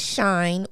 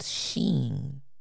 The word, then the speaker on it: Shein: It says, so if you've been calling it Shine or Sheen and correcting your friends who say otherwise, you might actually be the one in the wrong. Mm. The name is pronounced Sheen.